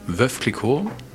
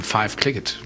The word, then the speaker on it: Veuve Clicquot: wie der Amerikaner das ausspricht. Und da ist eben Veuve Clicquot, ist eben Five Clicquot.